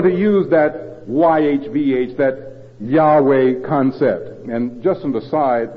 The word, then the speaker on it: Yahweh: Yahweh is how it's commonly pronounced.